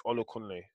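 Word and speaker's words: Olakunle: Everyone else, my name is Ola Kunle. Simple. That's my whole government name. Fuck it. But yeah, let them know you gonna pronounce it. It's three syllables. You can get that.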